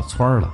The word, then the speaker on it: 汆儿: 那么回到我们要说的面条，同样的汆，到了吃面条的时候呢，北京人的儿化音比较重，就约定俗成的念成了汆儿了。